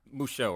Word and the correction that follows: Musou: or I can never pronounce it correctly. – Musou. – Musou.